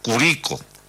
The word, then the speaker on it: Curico: O sea, se escribe igual, pero se marca de otra manera. La de la séptima región es Curicó, y acá hay un sector al lado del Ebu que se llama Curico, maestro.